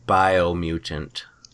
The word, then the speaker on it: Biomutant: Wolfenstein Youngblood and Biomutant. – Biomutant. – I think it's pronounced Bio. – Oh. – Yeah, mutant.